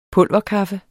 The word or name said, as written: pulverkaffe